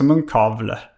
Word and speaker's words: munkavle: Jag har ju belagts med, med munkavel. – Exakt. – Eller heter det munkavle? – Fan, jag, jag har sett munkavle. – Det, och det ska uttalas också munkavle.